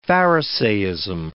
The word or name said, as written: pharisaism